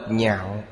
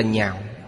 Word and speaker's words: nhạo: Chữ này đọc thành thứ tư, đọc nhạo là yêu thích, nếu đọc lạc là vui thích. Đây là chữ phá âm. Ở đây đọc là nhạo.